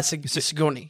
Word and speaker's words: Sigourney: Sigourney Weaver, right? That's how you say her name. – Sigourney.